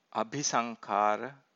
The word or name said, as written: abhisankhara